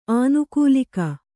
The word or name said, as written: ಆನುಕೂಲಿಕ